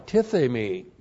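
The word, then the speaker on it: tithemi (τίθημι): T-I-T-H, long E-M-I, tithemi.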